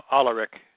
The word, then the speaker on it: Alaric: Did I pronounce it right, Mark? – No, Alaric. – Alaric, yes, but I did get the I in there.